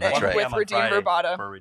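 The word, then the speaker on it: Radim Vrbata: And they'll sound nothing like anything we ever say, including his own name, which sounds like this, Redeem Verbata.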